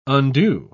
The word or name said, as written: undue